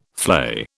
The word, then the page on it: flay: British English